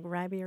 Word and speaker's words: ribey: They were pretty adamant about the pronunciation. The spelling was hotly debated. – Yeah, well, in the Dictionary of American Regional English, the main entry is for R-I-B-E-Y, ribby. – R-I-B-E-Y.